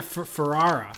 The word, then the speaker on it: Ferrara: It's Abel Ferrera. Am I saying his name wrong still? – Yeah, Ferrara. – Ferrara.